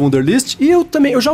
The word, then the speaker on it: Wunderlist: é o Wunderlist, ou Wunderlist para as pessoas que gostam de falar a pronúncia certa dele, né? A patrulha do inglês, cara. Não, né? essa é a patrulha do alemão ou do holandês, sei lá.